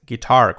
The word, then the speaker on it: guitar: No es guitar, es guitar, con acento en la y con la acabada en R, guitar.